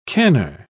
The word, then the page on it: canner: British English